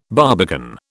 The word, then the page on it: barbican: British English